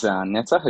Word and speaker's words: Netzah: Okay, so my name is Netza, as you said.